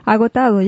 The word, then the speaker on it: agotado: Agotado, yeah.